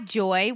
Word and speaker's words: Joi: I know, but I'm pretty sure you said J-O-I. – Yes, that's how they spell it. J-O-I, Joy Wellness. J-O-I Wellness.